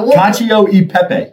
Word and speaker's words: Cacio e Pepe: There's no D. – Just the A. Cacio. – Cacio e Pepe.